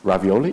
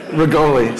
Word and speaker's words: Rigoli: And now with that, the script on the page, I'm going to attempt to invite Mr. Ravioli? No. – Rigoli. – Sorry, my apologies. Didn't mean to sound. – Olivero Rigoli. – Olivero Rigoli. Did I spell it right? – Yes.